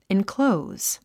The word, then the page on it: enclose: inklóuz; インクロウズ